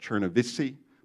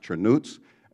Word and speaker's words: Chernivtsi: Americans would pronounce that Chernivtsi, but it's pronounced Chernuts, and then Cluj, down in Romania.